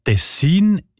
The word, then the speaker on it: Tessin: Aber der SRF-1-Hörerin Regula Stern-Grieser von Locarno ist aufgefallen, dass gewisse Leute statt Tessin Tessin sagen, also Betonung auf mehr, mit einem kurzen I.